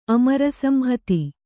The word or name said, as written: ಅಮರಸಂಹತಿ